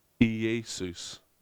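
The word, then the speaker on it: Iesous: Do you know that Jesus in there is the Greek word Iesus. Iesus is how it's pronounced.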